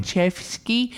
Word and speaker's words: Chayefsky: Chayefsky. – That guy. – And Patty is P-A-D-D-Y, so I believe it's a fella, not a lady.